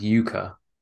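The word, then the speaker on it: yuca: So it's pronounced yucca, Okay. – but it's not the same as the yucca plant.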